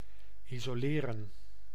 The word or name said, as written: quarantine